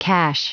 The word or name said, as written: cache